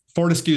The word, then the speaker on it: Fortescue: If you take Fortescue is my last name. So if you take off the last syllable, 40 is what people called me for years and years.